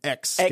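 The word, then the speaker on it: Xscape: Also, Escape, Lil' Kim, yeah. DeBrat. – Excuse me. I think you mean X-scape. – X-scape. I didn't put enough emphasis on the X.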